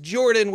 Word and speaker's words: Jorphdan: My name is Jordan with a silent PH in the middle, and I am joined always by my wonderful co-host, Sir Lucian, over at Sir Lucian Gaming.